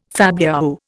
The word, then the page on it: fabliau: Indian